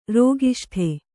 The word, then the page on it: ರೋಗಿಷ್ಠೆ: rōgiṣṭhe